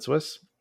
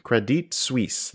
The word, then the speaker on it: Credit Suisse: Actually, I got Coil Spotting was kind enough to tell us how to properly pronounce that. – Okay. Suisse. – Sorry, Coil. I'm looking for your message. – Stussy. – Credit Suisse. That is the proper pronunciation. Credit Suisse.